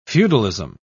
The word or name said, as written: feudalism